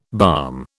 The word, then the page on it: balm: British English